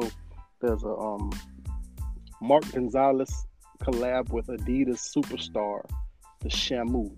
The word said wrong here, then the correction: Shmoo: The Shmoo. I'm sorry. Shmoo. Shmoo. Shmoo. I said that wrong.